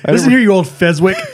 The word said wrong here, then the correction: Fezziwig: He even said that wrong. – What? – It's Fezziwig.